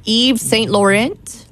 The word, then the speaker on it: Yves Saint Laurent: I can never say that perfume. Yves Saint Laurent. – Fine. That's... – He passed away on this day in history. I hope I said it right. – Close. Close enough.